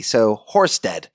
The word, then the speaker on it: Horsted: That's spelt C L I N I C for clinic, and Horsted, yes, a little bit tricky Danish word, is spelt H O R S T E D, so Horsted.